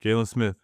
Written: Jalen Smith

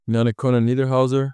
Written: Yanic-Konan Niederhauser

